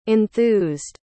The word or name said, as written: enthused